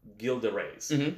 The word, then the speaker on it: Gilles de Rais: I'm so glad you pronounced it like that, because that's what I was pronouncing in my head the whole time. – Oh, yeah, it's Gilles, yeah.